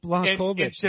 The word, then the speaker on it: Błachowicz: It's Błachowicz.